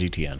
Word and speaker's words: Etienne: So I think you start making sure Etienne doesn't beat you. ETN, yeah. However you want to pronounce it. – The correct way. – Do you have that good authority? It's ETN? – It is ETN.